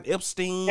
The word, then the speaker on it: Epstein: Epstein.